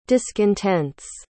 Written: discontents

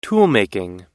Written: toolmaking

